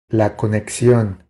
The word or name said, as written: La conexion